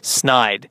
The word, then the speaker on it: Snyder: It's just if people could look at the name phonetically, S-N-Y-D-E-R, and just put together that it's not the Schneid, it's Snide.